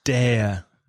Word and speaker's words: dare: Let's look at DARE now, which is atreverse in Spanish. Now, DARE, D-A-R-E, is pronounced similarly to words like there and where and pair. So dare, and like need, similarly to need, it can also be an ordinary verb, un verbo principal, and a modal auxiliary verb.